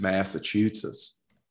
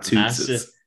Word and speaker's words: Massachusetts: Maybe I've been saying it wrong my entire life, but I believe it's Massachusetts, not Tootsits.